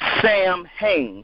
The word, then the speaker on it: Samhain: It was a festival pronounced Samhain, and that's Samhain, Samhain.